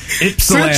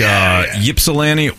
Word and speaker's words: Ypsilanti: We'll take Ypsilanti or Ypsilanti. I think the locals say Ypsilanti. Either way, it's not Ysplanty.